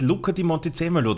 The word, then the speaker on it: Luca Di Montezemolo: Ich glaube, da arbeitest du gemeinsam mit Luca Di Montezemolo daran, oder? Hast du das jetzt überhaupt richtig ausgesprochen? Spricht man das so aus, Martin? – Ja, würde ich auch so machen.